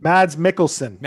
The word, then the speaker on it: Mads Mikkelsen: Was it Mads? Markkinen? – Mads Mikkelsen.